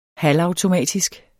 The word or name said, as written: halvautomatisk